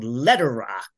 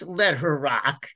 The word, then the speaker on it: Lederach: There's another favorite of mine down in that area that I like to recommend to everybody called Letter Rock, LederAch, pronounced Letter Rock, if you like.